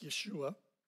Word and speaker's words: Yeshua: The actual pronunciation of his name in Hebrew would have been something like Yeshua.